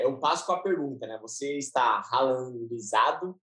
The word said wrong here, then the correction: Haalandizado: Caramba, hoje minha, minha pronúncia tá, tá ótima. Ralandizado? Ralandizado. – Caramba, eu tô com a língua presa hoje. Mas, enfim, eu tô totalmente Haalandizado.